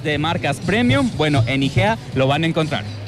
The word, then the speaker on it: Hygea: Están como IGEA Óptica. IGEA se escribe H-Y-G-E-A. – Exacto, correctamente.